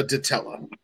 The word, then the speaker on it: Ditella: So why is a Ditella called a Ditella? Am I saying that correctly? – Ditella is the correct term.